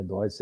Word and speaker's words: Deutz: Depois nós tínhamos a, a Ford, né, a Ford do Brasil, a mesma Ford que fazia automóveis, e a Deutz, alemã, ou Deutz, é, como queiram, né? Deutz é a pronúncia.